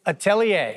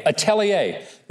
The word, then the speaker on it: Atelier: Atelier. – Atelier.